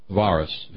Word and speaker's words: Varus: Varus, V-A-R-U-S, you don't pronounce the V as a V, you pronounce it as a W in Latin, got some 20,000 Roman soldiers massacred in the dark and terrifying confines of the Teutoburg Forest.